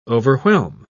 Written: overwhelm

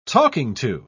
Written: talking-to

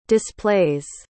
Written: displays

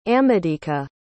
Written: amadika

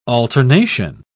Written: alternation

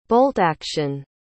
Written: bolt-action

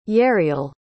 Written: yeriel

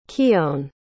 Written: kione

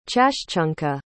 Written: chashchunka

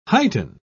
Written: heighten

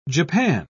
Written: japan

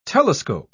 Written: telescope

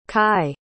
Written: kai

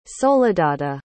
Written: soledada

